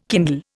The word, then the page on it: kindle: Indian